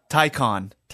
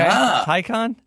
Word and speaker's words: Taycan: Taycan. – Yeah, it sure? Rhymes with icon, according to Edmonds. – Okay, Taycan.